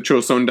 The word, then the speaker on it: Joseon: that is the Korean pronunciation of a Chinese phrase, Chaoxian, which was conferred upon the emperor of the Joseon Dynasty, or the king of the Joseon Dynasty, by Zhu Yuanzhang, the founder of the Ming Dynasty.